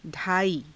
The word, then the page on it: _dhaaii: Hindi